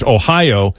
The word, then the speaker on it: ohayo: If it's in the morning, they say, Ohio, it's pronounced like O-H-A-Y-O, but it's pronounced Ohio, and gozaimasu, which is sort of like a formal way of saying, of emphasizing whatever it is you're saying.